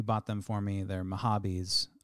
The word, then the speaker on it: Mahabis: And I've been mispronouncing Mahabis as Mahibis for years, thinking it was a funny bit.